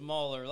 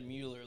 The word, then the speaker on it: Muller: Yeah, it's Muller. Lots of people say Mueller, like Bueller.